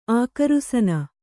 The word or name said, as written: ಅಗ್ರಾಸೀನೆ